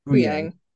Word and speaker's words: Huyang: Huang? How do we say it? – Huyang. – Huyang. Sorry.